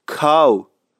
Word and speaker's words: کاهو: و نکته دیگه این که اصفهانی ها کاهو رو کاهو تلفظ میکنن. یادتون باشه هر جا حرف ه بود بدون این اتفاقی میافتد. کاهو هم یکی از همون هست. اصفهانی ها به کاهو میگن کاو. تکرار میکنم کاو.